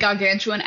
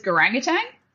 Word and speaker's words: gargantuan: I'd never said gargantuan out loud for any reason. I didn't realize, and so whenever I read it as garangatang.